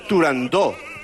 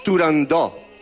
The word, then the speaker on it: Turandot: Además no puede ser Turandot, es Turandot.